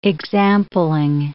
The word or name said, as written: exampling